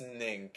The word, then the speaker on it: Ning: What is Ning? – Ning. It's spelled N-I-N-G, and it's a social networking site where you can connect to people and create a community, pretty much, that's very specific.